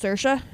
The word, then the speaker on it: Saoirse: No, but like I feel like the S-I-D-H is going to be like something like... what's her name? Like Saoirse. You know, like the word that's S-O-I-R-S-E. And it is pronounced Saoirse.